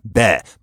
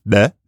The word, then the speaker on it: be: It's fun to say, actually, because it's an implosive B. You can pronounce it that way. So it's not be, but it's be. Beh, it's fun.